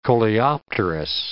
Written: coleopterous

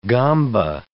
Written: gamba

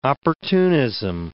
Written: opportunism

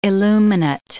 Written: illuminate

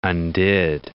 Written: undid

